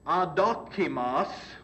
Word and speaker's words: adokimos: It's adokimos. You can spell it A-D-O-K-I-M-O-S.